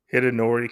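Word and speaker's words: Hidenori: Write notes to Hidinario Kusaka? I don't know. I probably butchered that. – Hidinori Kusaka? – Yeah, Kusaka.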